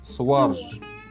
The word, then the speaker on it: Сваржиш: Слитно. И ударение упадет Сваржиш. на а. – Сваржиш.